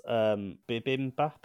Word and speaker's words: bibimbap: Also, what's bibimbap? – It's pronounced bibimba.